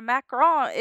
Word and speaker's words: macaron: A macaroon has coconut in it and is gross, but a macaron is a macaron. It's French. That's how you say it.